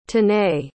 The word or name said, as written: tanay